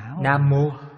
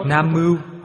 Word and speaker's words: Nam Mô: Quý vị đọc Nam Mưu họ không hiểu. Đọc Nam Mưu người nào hiểu? Người thời Đường hiểu hai chữ Nam Mô này, người thời Đường đọc là Nam Mưu. Dịch kinh là thời đó dịch. Thời đó dịch niệm không sai. Bây giờ âm này đổi rồi.